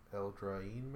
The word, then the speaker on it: Eldraine: Eldraine. – I believe that's how you pronounce it. It's E-L-D-R-A-I-N-E. – Eldraine, maybe? – Yeah.